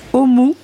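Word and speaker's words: Ohmu: Euh, moi, euh, cette fois-ci, ma recommandation, c'est une toute jeune artiste qui s'appelle Omu. Alors ça s'écrit O-H et plus loin M-U.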